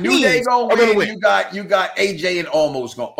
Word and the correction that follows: Omos: Oh shit, I said his name wrong. – Omos going to win.